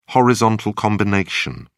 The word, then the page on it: horizontal combination: British English